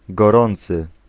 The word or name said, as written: cordial